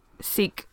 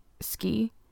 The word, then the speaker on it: Sikh: I always want to call them ski because of the way it's spelled. – Oh, yeah, yeah. – But it's one of those things when you verbalize it, you know how to say it.